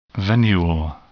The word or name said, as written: venule